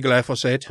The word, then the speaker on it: glyphosate: How do you say? It? Which way do you say it? – I say glyphosate.